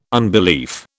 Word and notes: unbelief: British English

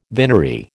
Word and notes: vinery: American English

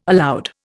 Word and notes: aloud: Indian

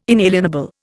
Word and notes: inalienable: Indian

